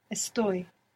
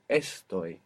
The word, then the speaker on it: estoy: And the other irregularity that we have is that this verb is the only verb in the entire Spanish language that doesn't have the accent on the penultimate syllable in the present tense. So it's not estoy, it's estoy. The accent's on the ending.